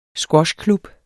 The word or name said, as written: squashklub